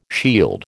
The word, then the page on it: shield: American English